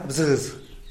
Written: bzïz

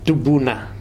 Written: tupuna

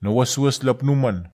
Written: nowaswas lapnuman

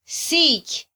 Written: siky

